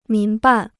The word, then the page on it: 民办: mín bàn